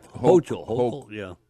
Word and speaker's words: Hochul: If you look at New York with the mayor there, what's it, Hulk? How do you pronounce that, their last name? I think it's Hulk or something like that. – Hochul.